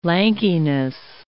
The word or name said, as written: lankiness